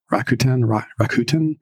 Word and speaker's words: Rakuten: Rakuten? – Oh, yeah.